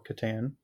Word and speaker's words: Catan: So Josh's answers were Settlers of Catan, or Catan, depending on your pronunciation.